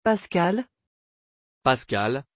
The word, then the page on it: paskal: French